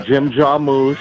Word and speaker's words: Jim Jarmusch: Maybe I was on set at the limits of control with director Jim Jarmusch. Maybe I was on that. – That's how you pronounce it.